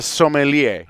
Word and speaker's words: sommelier: So what is that word again? – A sommelier. – I can spell it. I can't say it. It's sommelier.